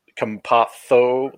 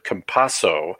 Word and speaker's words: Campazzo: Actually, if you're in Spain, you pronounce it Campazzo, like the TH on the Z's.